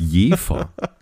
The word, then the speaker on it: Jever: ähm, kleiner Insider, dass das Jefer ausgesprochen wird eigentlich.